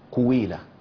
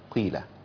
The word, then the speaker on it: قيل: يعني بعض الإخوة بنشوفهم بيقولوا يقرأوا فيقول إيه وإذا قويلة، ففي الحقيقة ده مش إشمام، أنت كده ضميت القاف وزودت واو مكسورة كمان. البعض يقول وإذا قويلة، نفس الحكاية، تغيرت بس اللهجة يعني، ضميت القاف وزودت واو، ولكن النطق الأصح أن تضم شفتين قبل الحرف، تقول وإذا قيل، قيل، خدت بالك؟